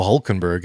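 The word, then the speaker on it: Hülkenberg: Then we had row four was Lando Norris and Oscar Piastri, and then row five we saw Lewis Hamilton and Nico Hulkenberg. And no, I did not say that incorrectly uh, the Hulk, Pierre uh, finishing tenth in qualifying.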